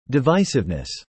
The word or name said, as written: divisiveness